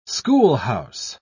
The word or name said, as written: schoolhouse